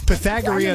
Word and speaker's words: Pythagorean: It's Pythagorean.